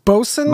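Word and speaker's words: boatswain: That word is pronounced bosun? – Bosun. – Even though there's all sorts of extra letters in there.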